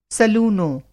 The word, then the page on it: सलूनो: سلونو